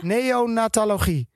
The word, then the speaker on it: neonatologie: Neo, nee, wacht even. Neonatologie.